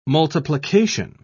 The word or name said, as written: multiplication